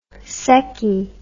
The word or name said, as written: seccy